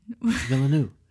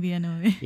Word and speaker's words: Villeneuve: Yeah, I was saying Villeneuve. – Yeah, he's French, so definitely a different pronunciation.